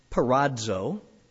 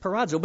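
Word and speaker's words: parazo (πειράζω): Well, this one word is pronounced parazo. You'll probably forget that as soon as I do, but parazo.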